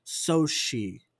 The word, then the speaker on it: Xochitl: It's her name is pronounced Soshi.